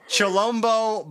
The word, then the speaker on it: Chilombo: Chilombo by Jhené Aiko.